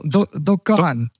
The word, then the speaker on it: Dukan: No, Dukan. – Dukan. Right. – Yeah. – Always been. You're supposed to stretch the A, so not Kan.